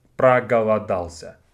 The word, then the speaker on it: проголодался: So let's break down проголодался. It's quite a long verb, but it's pretty easy to pronounce. There's not a lot of hard combinations of sounds. So the first syllable is пра. Пра, га, ла.